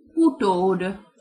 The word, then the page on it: utod: Hungarian